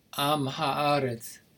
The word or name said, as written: am haaretz